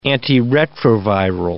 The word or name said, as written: antiretroviral